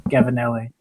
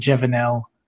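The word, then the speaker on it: Gevenalle: So I was at VeloCope one time when I first expressed interest, and the sales rep was there, and he said, yeah, it's Gavinelli. But then I've heard other industry people pronounce it Gevinel. And so I alternate.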